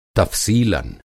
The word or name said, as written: तफ़सीलन